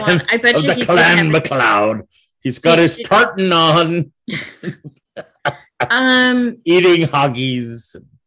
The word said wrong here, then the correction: haggis: No. – Haggis. – Did I say that wrong? – Haggis. Yes, it's Haggis.